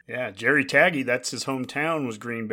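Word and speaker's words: Tagge: Jerry Tague, is that how you say it? – Taggy.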